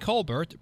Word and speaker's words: Colbert: Colbert, I think it's Colbert, isn't it? Well, that's okay. We can use the French pronunciation or Americanize it to Colbert, but I do believe Colbert is correct.